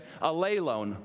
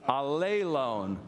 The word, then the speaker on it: allelon: Allelon, one, two, three. Allelon. There you go. It rhymes with alone.